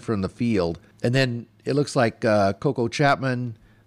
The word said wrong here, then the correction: Koa: Koa is how you say it, but Koa, will be counted on heavily.